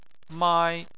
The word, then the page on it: mai: 1